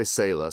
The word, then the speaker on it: Selah: Is it Saleh Wines? – There's a couple different ways to say it. We say Selah, so it means to pause and reflect.